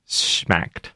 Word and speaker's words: Schmacked: Schmacked.